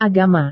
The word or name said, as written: agama